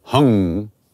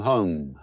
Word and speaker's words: hum: Sometimes it's pronounced hung, not hung, that's something else.